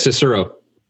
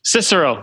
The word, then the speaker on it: Cicero: I think that's Cicero. – Cicero.